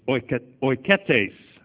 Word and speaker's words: oiketes: Another one's only used 4 times, translated servant, is oiketes. Oiketes. It's spelled O-I-K-E-T-E-S, O-I-K-E-T-E-S, 3610 in Strong's, and it means a household servant, a household servant.